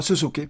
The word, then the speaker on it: Suzuki: Eller uttalas det så? – Ja, Suzuki. Ja, Shinichi. ja, ja. det är rätt.